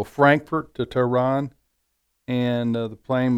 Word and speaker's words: Tehran: And I remember somebody telling me, a friend of mine said he flew into Tehran. Is that how you pronounce Tehran. – It? – Yeah. – Tehran.